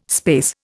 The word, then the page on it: space: Indian